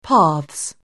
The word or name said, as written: paths